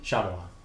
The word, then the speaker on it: Shyamalan: Shyamalan.